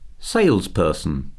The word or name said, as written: salesperson